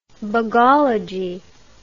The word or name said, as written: bugologist